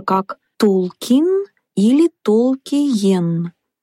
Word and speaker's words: Толкин: Но сам он указывал, что всегда произносит окончание как «кин». Так что вариант «Толкин» более точно отражает произношение фамилии. Некоторые члены семьи писателя даже ставили ударение на последний слог «Толкин».